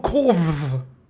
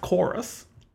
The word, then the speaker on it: Chorus: It's called Corvs. I was convinced that was a V. It's just stylized V. It's pronounced Chorus.